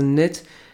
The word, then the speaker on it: knit: Der Name Knit kommt von dem Material gestrickt, also Knit, ich glaube, das K wird nicht ausgesprochen, also Knit, ist in dem Fall für Englisch für gestrickt, wenn das richtig übersetzt worden ist.